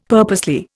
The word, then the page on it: purposely: Indian